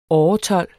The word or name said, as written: åretold